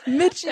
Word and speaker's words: Mitchell: Like when teachers are like, Miko. Mm-hmm. Miko, and I'm like, no, Mitchell.